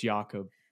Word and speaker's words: Jakob: But he's Austrian, so it's Jakob.